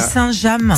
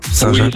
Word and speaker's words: Saint-James: Je crois qu'on dit Saint-James. – Saint-James peut-être alors. – Saint-James, on prononce pas le. – D'accord. – Le James, on n'est pas anglais.